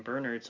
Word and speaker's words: Bernard: No, yeah, I was like, I was about to, like, I just, like, when I was watching the special features, all the actors and writers were calling him Bernard, so I'm like, oh, well, I'm going to make sure that when I say it on the pod, I sound all fancy and pronounce it correctly.